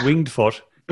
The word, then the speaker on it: Winged Foot: I was like going, thinking to myself, do I pronounce it winged foot or winged foot? But it's winged foot, for anybody wondering.